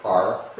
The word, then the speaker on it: Ars: Ars, or the French call it R. This S is silent.